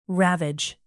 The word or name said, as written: ravage